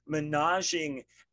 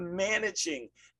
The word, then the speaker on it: managing: Say it right. It's not the menaging expectations podcast, it's the managing expectations podcast.